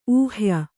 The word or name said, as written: ಊಹ್ಯ